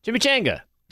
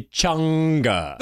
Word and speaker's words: chimichanga: Chimichanga. – Say it right. – What? – She's laughing at your pronunciation. – Oh, I'm sorry, chimichanga.